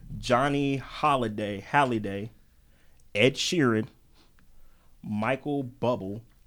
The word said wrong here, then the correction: Bublé: Bublé. – He said Bubble. – B-U-B-L-E? – Bublé. – Bublé. Okay. Yeah. They didn't have the accent on there.